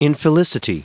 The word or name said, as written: infelicity